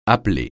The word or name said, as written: appeler